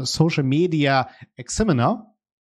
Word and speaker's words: Social Media Examiner: und zwar die Kolleginnen vom Social Media Examiner.